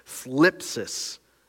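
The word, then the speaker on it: thlipsis (θλῖψις): That Greek word for trouble is this word thlipsis. I didn't mispronounce it. There's some vowels that I feel like are missing from the beginning of it.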